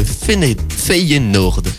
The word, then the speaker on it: Feyenoord: Feyenoord. Tu l'as bien prononcé.